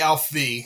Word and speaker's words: ALPHV: Do you say AlphaV? Do you say Alf V? – I say Alf V because there's no A in there.